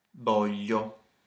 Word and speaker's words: voglio: Voglio. – So you get, that's voglio. Voglio. – Voglio. Exactly. – Now, how would you say I want lasagne and gnocchi? – Voglio lasagne e gnocchi.